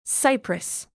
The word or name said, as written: cypress